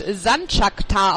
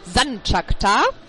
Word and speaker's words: Sanchakta: Der Torhüter der SG wird Sanchakta ausgesprochen. Betonung auf der ersten Silbe. Sanchakta.